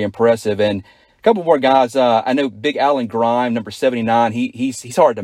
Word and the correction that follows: Grimmie: It's pronounced Grimmie. – Grimmie. I'll make sure we get that right on the air, Coach.